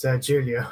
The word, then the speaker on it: Julio: I hope I'm pronouncing your name correctly. – It's Julio, actually. – Julio, okay.